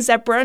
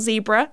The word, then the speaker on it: zebra: You know, we say zebra, not zebra.